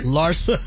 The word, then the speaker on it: Larsa: Larsa. That's how you say her name.